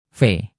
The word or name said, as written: v